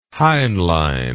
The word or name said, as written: Heinlein